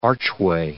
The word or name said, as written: archway